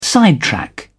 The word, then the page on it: sidetrack: British English